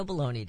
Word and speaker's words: bologna: Sometimes this specialty meat is spelled B-A-L-O-N-E-Y, but that's not the, that's kind of the way they pronounce it, but it's not spelled that way. It's B-O-L-O-G-N-A. We remember the little jingle. My bologna has a first name. It's O-S-C-A-R.